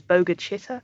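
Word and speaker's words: Bogue Chitto: But it does eventually appear that they managed to buy their own land because the community that they lived in, it's called Bogue Chitter, but I think it's pronounced locally as Boga Chitter.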